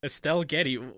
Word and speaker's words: Estelle Getty: Etzel Getty? – You're definitely saying that wrong.